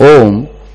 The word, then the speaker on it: Om: In fact, there is an explanation for Om. Om has three letters, three syllables, A, U, Ma. A represents the Purushottam, Lord, Supreme Lord.